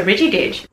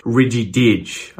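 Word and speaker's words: ridgy-didge: Something else just stuck out like a sore thumb there, and it was the emphasis that Leah gave Ridgy Didge. She said all the syllables equally. – These are Ridgy Didge. – Ridgy Didge. Ridgy Didge. Whereas Australians would say Ridgy Didge. I think we would put most of the emphasis on Didge here.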